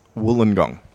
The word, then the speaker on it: Wollongong: Wollongong.